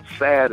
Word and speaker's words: Saad: Sad is spelled S-A-A-D.